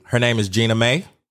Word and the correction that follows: Jeannie Mai: You done butchered her name so much. I'm over here. Jeannie Mai. – Oh, Jeannie Mai.